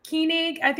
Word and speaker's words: Koenig: It's German. So it means king in German. Koenig is how they say it in Germany.